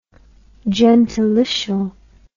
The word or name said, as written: gentilitial